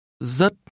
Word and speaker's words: rất: Rất. – Is pronounced with a rising tone.